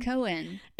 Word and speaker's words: Cohen: Cohen.